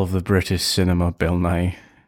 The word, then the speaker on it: Nighy: I didn't realize if there was a T in there. So Bill Nighy.